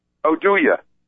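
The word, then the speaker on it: Oduya: Oduye. – Shane? Huh? – Oduye. You got to say it just like Oduye? It's exactly how you say his name.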